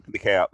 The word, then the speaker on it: DeKalb: So, how you pronounce this? DeKalb County? – Yeah, DeKalb, yes.